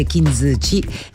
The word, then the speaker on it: kintsugi: È una logica meravigliosa anche per la vita, e questa parola è kintsugi, è giapponese, come dicevo, la U non si legge, quindi si dice kintsugi, e questo è il nuovo singolo di Elisa.